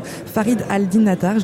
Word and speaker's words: Farid al-Din Attar: Attention, je vais tenter de bien prononcer le nom de cet auteur persan, Farid al-Din Attar. Je l'ai bien prononcé. – Très bien, parfait.